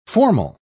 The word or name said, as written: formal